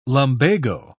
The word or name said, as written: lumbago